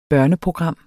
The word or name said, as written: børneprogram